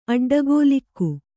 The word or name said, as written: ಅಡ್ಡಗೋಲಿಕ್ಕು